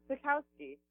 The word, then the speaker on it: Sikowski: Sikowski. That's how you pronounce my last name. I know it's complicated. – No, it's not. You're a Paisan, okay? Sikowski. if it were my family, they would probably say Chikovsky or something like that, right? Have you heard that? – Yeah, I think I have, lots of people like to say it like Chukowski, even though there's no H.